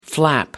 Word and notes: flap: American English

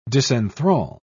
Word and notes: disenthrall: British English

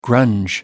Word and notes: grunge: American English

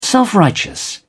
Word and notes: self-righteous: British English

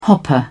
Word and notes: hopper: British English

